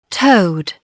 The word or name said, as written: toad